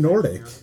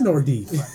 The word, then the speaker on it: Nordiques: It is Nordic. It is spelled Nordiques. – Oh. – It is Nordic. – I thought it was Nordi.